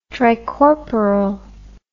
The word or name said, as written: tricorporal